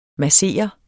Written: MASSERES

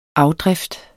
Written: afdrift

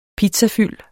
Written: pizzafyld

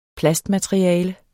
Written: plastmateriale

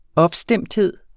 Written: opstemthed

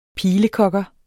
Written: pilekogger

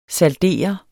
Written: saldere

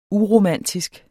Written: uromantisk

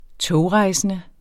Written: togrejsende

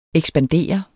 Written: ekspander

